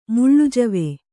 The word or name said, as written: ಮುಳ್ಳುಜವೆ